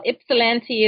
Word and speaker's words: Ypsilanti: Ypsilanti? – You did very well. Ypsilanti is, it's a beautiful little town.